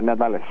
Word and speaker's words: Nadales: Am I pronouncing your last name right, Gabriel? – Yeah, Nadales.